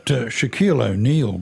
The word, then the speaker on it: Shaquille O'Neal: But Shaquille O'Neal gave a 15-second appearance at a press conference in favour.